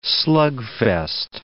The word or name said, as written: slugfest